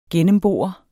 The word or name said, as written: gennembore